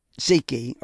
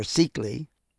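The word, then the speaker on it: Szekely: And that was all put together by Edmund Seeky, or Seekley. They pronounce it a couple different ways in Hungary.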